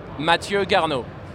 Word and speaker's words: Mathieu Garnault: How do you say your full name? – Mathieu Garnault. – Wonderful.